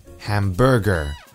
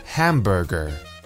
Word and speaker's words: hamburger: Because of Cantonese, many people in Hong Kong tend to place the emphasis on the second syllable for words that have three syllables. For example, they would say cucumber and hamburger.